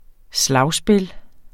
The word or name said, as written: slagspil